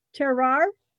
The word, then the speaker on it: terroir: Is it terroir? What? Say it again. – Terroir? – Terroir.